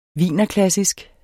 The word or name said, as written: wienerklassisk